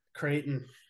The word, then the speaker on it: Creighton: Well, I picked Creighton. Is that how you say it? Creighton? Creighton? – Creighton.